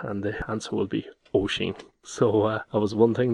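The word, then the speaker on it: Oisín: I didn't know what name it was, and I downloaded the PDF of the book to find out that the spelling was O-I-S-I for the N, and that is pronounced O-Sheen. It's not pronounced O-Sin, it's O-Sheen, O-Sheen.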